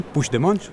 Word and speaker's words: Puigdemont: ¿Lo dije bien? Puigdemont. Sí, tal cual.